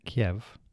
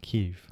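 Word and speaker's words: Kyiv: They're not saying Kiev. They're saying Kiev. – Kiev, because it's how it's said in Ukrainian.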